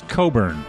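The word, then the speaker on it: Cockburn: That was recorded by Bruce Coburn.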